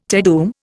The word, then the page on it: Te Deum: Indian